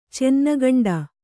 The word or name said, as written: ಚೆನ್ನಗಂಡ